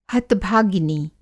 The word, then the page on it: हत-भागिनी: Hindi